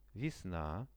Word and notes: vesna: Russian

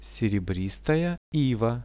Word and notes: serebristaja iva: Russian

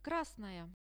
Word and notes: krasnaya: Russian

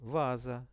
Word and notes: vaza: Russian

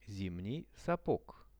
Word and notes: zimnij sapog: Russian

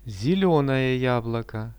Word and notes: zelionoe jabloko: Russian

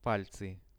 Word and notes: palcy: Russian

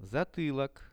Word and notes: zatylok: Russian

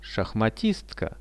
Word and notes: shahmatistka: Russian

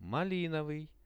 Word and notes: malinovyj: Russian

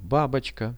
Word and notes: babochka: Russian

